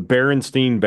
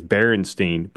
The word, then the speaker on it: Berenstain: Most people remember the name as Berenstain, but it's actually Berenstain. – Baron Stein. – It's not. It's not. Pronounced. It's not Stein on the end. It's Stain, like stain.